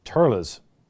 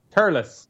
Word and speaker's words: Thurles: And Pronounce it as though it's spelled T-U-R-L-I-S. – Thurles. – Thurles. Yeah. Thurles.